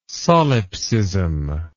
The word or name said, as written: solipsism